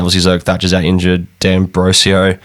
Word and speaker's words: D'Ambrosio: D'Ambrosio, is that how you say it? Yeah. D'Ambrosio. – D'Ambrosio, apparently.